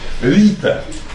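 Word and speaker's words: ṛta: Это слово восходит к индоевропейскому, санскритскому, древнейшему корню рита, короткое такое ри, санскритологи пишут латиницы, обозначают буквой А с точкой внизу, рита. Рита.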